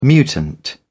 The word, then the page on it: mutant: British English